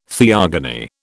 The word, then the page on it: theogony: British English